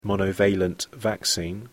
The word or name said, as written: monovalent vaccine